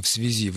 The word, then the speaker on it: в связи: Антонина, конечно, «в связи» в любом случае, независимо от значения, когда мы говорим «в связи с чем-то», ударение, разумеется, на «и», но никакого слитного написания ни в каком случае быть не может.